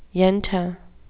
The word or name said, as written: yenta